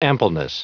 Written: ampleness

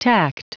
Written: tact

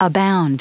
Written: abound

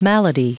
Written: malady

